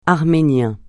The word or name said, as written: Arménien